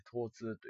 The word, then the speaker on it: 疼痛: 考えられてますかえー、っとですね、痛みはですね、実は、あの、疼痛学会っていう、あの、痛みのことを医学用語で疼痛と言って、疼痛の疼は、あの、病れに、えー、冬と書いて疼と読むんですけども、